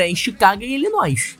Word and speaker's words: Illinois: Isso, Illinois, é isso.